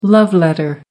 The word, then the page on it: love letter: American English